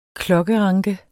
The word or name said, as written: klokkeranke